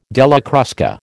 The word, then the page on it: Della Crusca: American English